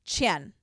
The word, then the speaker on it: Qian: How do you say the last name? – Qian. – Oh, Q-I-A-N, Qian.